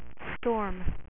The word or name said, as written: Storm